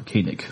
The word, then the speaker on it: Koenig: Walter Koenig. Koenig. Mm-hmm. I always want to say Koenig. Koenig. It, it's, Koenig. it's spelled that way, but it's just not pronounced yeah. that way.